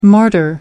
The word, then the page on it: martyr: American English